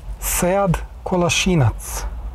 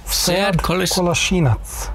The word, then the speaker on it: Sead Kolašinac: Sead Kolašinac. – There. – Oh. – Sead Kolašinac.